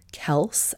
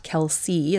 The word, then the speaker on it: Kelce: The long-rumored romance between she and American football player Travis Kelce, I'm told this is pronounced Kelce, not Kelsey, as many people have verbally adopted,